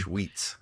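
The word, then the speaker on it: Schweetz: Sweets.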